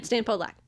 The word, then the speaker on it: Podolak: Stan Podolak. – Have to say it like that. That's how he says it. That's how he introduces himself to everybody.